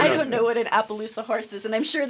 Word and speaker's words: Appaloosa: Apparently, the Appaloosa horse is the Idaho state animal. Did I say that right? Maybe. – You did.